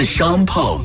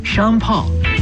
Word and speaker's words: Sean Paul: 起来有点意思，这是一首 20年前的歌，叫做 Give It Up To Me，来自 Sean Paul 和 Keisha Cole。你说多奇怪， 20年前的歌，很多人都把它忘了，这歌又突然被另一代人重新爱上，变成 brand new track。这也充分的说明，好的音乐就是会轮回的。介绍一下演唱者 Sean Paul，听着发音有点像啊，山炮，sorry，但其实呢，他是牙买加的鬼王，雷鬼的鬼，当年把雷鬼节奏带进了全球流行市场。